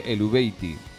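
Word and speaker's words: Eluveitie: Carries tirando de Google, vamos a intentar pronunciar el nombre de El Veiti, que es, se lee El Veiti.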